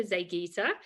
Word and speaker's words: Zeigyte: This is Erika Zeigyte, who is the founder and CEO of Prosana.